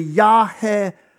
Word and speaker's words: Yahweh: And we don't even really know exactly how it would be pronounced, but by now there's scholarly consensus that essentially, if you were to read this in the ancient Hebrew, it's a very old language, that it would be essentially Yahweh.